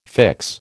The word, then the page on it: fix: American English